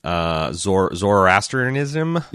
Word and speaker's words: Zoroastrianism: And I also like, because you talk in the book about how R'hllor is very similar to the real life religion, Zoroastrianism.